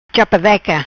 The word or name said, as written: Chapadeca